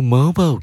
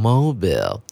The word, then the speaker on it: mobile: Play mobile games 啊，玩手游。那这个字呢，有人会念成 mobile。那班老师要建议大家念成 mobile，是大家比较能够接受的念法哦。